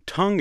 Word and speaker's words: Tunng: Tongue is their name, spelled T-U-N-N-G.